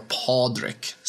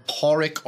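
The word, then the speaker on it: Pádraig: P-A-D-R-A-I-G. – Which is fascinating to me. – Or Pádraic or Pádraic, depending on how you want to pronounce it.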